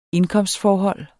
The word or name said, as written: INDKOMSTFORHOLD